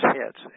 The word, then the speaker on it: Hets: Is Hets, H-E-T-S, pronounced that way. And then when you have the Babylonian name, they have changed it to a Chet.